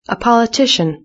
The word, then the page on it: a politician: British English